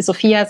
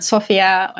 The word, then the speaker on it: Sophia: In Island ist es eben so, dass man quasi die umgedrehte Silbe betont. Ne? Also das ist dann nicht Sophia, sondern Sophia und genau.